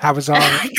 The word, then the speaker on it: Hawezar: Uh, so, yes, you're in a region called Hawezar. Am I saying that right? – I think you're close enough. Hawezar.